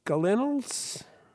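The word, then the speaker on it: gallinule: Michael had emailed us the common gallinules.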